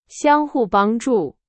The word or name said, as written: 相互